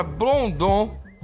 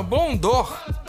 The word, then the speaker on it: Blondor: Outra coisa que eu fui alertado aqui é que a pronúncia da palavra blondon, do que a gente falou de descolorir os pelos, é que não é blondon, que no Rio se chama blondor.